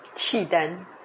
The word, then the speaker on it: Khitan: Khitan.